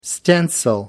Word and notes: stencil: American English

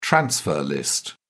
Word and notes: transfer list: British English

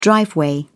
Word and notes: driveway: British English

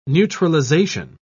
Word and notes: neutralisation: British English

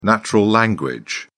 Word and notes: natural language: British English

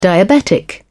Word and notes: diabetic: British English

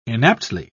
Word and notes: inaptly: British English

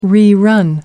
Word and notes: rerun: American English

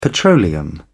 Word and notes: petroleum: British English

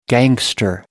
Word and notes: gangster: American English

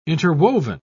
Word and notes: interwoven: British English

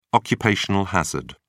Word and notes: occupational hazard: British English

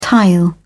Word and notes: tile: British English

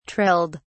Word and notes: trilled: British English

